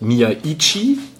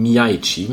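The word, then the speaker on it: Miyaichi: Ausgesprochen wird er, wenn man zumindest Philipp Herrwagen glauben darf, Miaichi. – Nicht wie ich oder auch Wolf das oft ausspricht, Miaichi, sondern Miaichi mit einem durchaus fließenderen Übergang zum ersten I.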